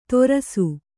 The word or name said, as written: ತೊರಸು